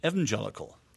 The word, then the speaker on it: evangelical: In England, I mean, this is ridiculous in a way, but in England we say evangelical. – Right. – Short E.